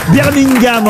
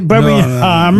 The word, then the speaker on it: Birmingham: Birmingham, en tout cas.